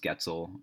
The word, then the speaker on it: Goetzel: My last name's Getzel, rhymes with pretzel.